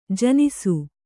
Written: ಜನಿಸು

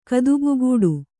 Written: ಕದುಬುಗೂಡು

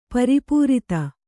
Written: ಪ್ರಪೂರಿತ

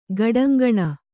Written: ಗಡಂಗಣ

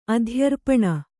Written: ಅಧ್ಯರ್ಪಣ

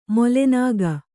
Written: ಮೊಲೆನಾಗ

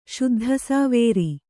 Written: ಶುದ್ಧಸಾವೇರಿ

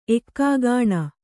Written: ಏಕಾಕಿನಿ

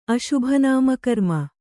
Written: ಅಶುಭನಾಮಕರ್ಮ

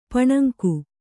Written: ಪಾನಾಂಗ

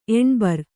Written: ಎನಿಬರ್

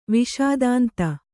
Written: ವಿಷಾದಾಂತ

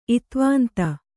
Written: ಇತ್ವಾಂತ

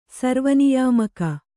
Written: ಸರ್ವನಿಯಾಮಕ

